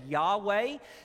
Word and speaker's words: Yahweh: The King James translated it as Jehovah, but we know today it was probably more correctly pronounced as Yahweh.